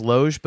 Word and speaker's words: Logue: I have to get the correct pronunciation, but I believe it's Logue, but it's Paul Logue. Our buddy Aiken says Loge, but I think it's Paul Logue.